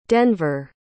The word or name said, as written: denver